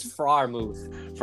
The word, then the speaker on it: Freiermuth: Look, I've been watching a lot of these videos to how to pronounce it. It's Freiermuth.